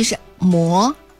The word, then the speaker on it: 嬷: 客家人日常里面的阿嬷就是奶奶或者是祖母，又或者是有些人读外婆也是读阿嬷。是方言嘛，阿嬷，可是呢这个正确的读音就是嬷。